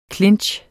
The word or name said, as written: clinch